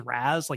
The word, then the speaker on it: Raz: Do I say it as Raz, like Razzle Dazzle? – Yeah, that's how I usually pronounce it.